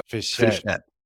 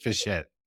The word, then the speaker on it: Fischette: Today we have with us David Fischette. David is the founder. Sorry, did I pronounce it correctly? – Almost. It's Fischette, like a small Fischette. Yeah. Perfect. David Fischette.